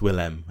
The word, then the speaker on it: Willem: And it's Willem, I think. – Willem Dafoe. – Yeah.